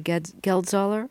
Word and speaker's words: Geldzahler: This is Henry Geldzahler. Am I saying his name right? – You are.